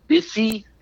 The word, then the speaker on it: Bisi: People, your surprise game and your surprise numbers are from a kid named Bissy Johnson. Spell it B-I-S-I, John S-O-N. Thank you. – You're welcome. – If you have a daily fantasy football thing going, Bissy Johnson's cheap.